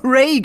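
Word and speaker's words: Rei: Sie heißt eigentlich Rai, wird auf Deutsch aber Ray genannt. Selbstverständlich, ist viel besser aussprechbar.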